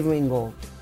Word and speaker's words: Ringwald: você tem a Molly Ringwald, Ringwald é isso? Molly Ringwald.